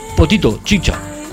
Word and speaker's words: Potito Chicha: No, perdón, es Potito Chicha. Cuidado con la pronunciación, ¿eh? Esto es muy importante. Porque Potito Chica es una cosa y Potito Chicha es otra. Porque, por ejemplo, Potito Chicha significa comida para bebés con carne.